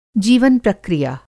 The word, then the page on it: जीवन-प्रक्रिया: Hindi